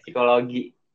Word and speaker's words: psikologi: Iya, psikologi. P-nya nggak bisa dibaca.